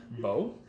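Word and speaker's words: Beaux: Did you say B A B E A U X? – Yes. – That would be Bo. – Bo. – Is the oh the X is silent? – Yes.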